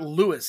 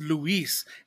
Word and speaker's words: Luis: Then his name is not Lewis. It's Luis. So you're pronouncing his name wrong the entire time.